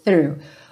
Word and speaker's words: through: Então, to hesitate is, e essa palavra through, não entre em pânico quando você vir T-H-R-O-U-G-H, oh my, não, through, ok? A pronúncia dela é bastante simples, mas tem que começar com a linguinha lá na pontinha dos dentes da frente, ó, linguinha pra fora da boca mesmo, through.